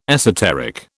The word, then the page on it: esoteric: British English